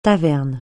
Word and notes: taverne: francais

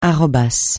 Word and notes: arrobas: francais

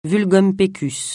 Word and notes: vulgum pecus: francais

